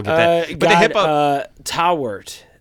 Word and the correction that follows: Taweret: uh, Tawerit, T A W E R E T, Egyptian god.